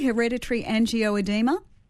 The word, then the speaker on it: hereditary angioedema: Did I pronounce that correctly? Hereditary angioedema? – Yeah, that's perfect.